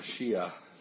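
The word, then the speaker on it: Mashiach (משיח): Mashiach.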